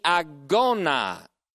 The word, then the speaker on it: agona: Exhortation is necessary because we are engaged in the agona. A-G, long O-N-A.